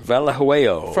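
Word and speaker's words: Vallejuelo: V-A-L-L-E. – That's good. – J-U-E-L-O. – What is it? – Valahuelo.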